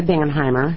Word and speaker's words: Bingenheimer: My name is Linda Bingenheimer. Do you want me to spell it? – Please. – B-I-N-G-E-N-H-E-I-M-E-R.